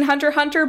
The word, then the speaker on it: Hunter x Hunter: I am familiar with, I have not seen Hunter x Hunter, but I know enough to know that it's pronounced Hunter x Hunter and you don't say the X.